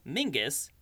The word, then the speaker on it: Menzies: That name, Mingus, despite being pronounced Mingus, is actually spelled M-E-N-Z-I-E-S.